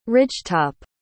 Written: ridgetop

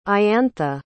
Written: iantha

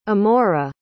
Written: amora

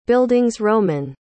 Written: bildungsroman

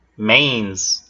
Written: manes

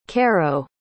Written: carrow

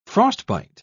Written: frostbite

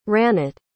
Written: Ranit

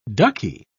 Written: ducky

